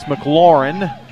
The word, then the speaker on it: McLaurin: I said McLaren, but it's McLaurin. I knew that. Just said it wrong there.